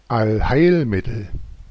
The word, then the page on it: panacea: American English; /ˌpæn.əˈsiː.ə/; /ˌpæ.nə.ˈsi.ə/